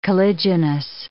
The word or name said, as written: caliginous